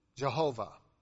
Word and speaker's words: Jehovah: Jehovah is how scholars pronounced it. Olden days, nowadays they prefer Yahweh, but historically the Y in Hebrew is a J sound.